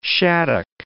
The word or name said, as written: shaddock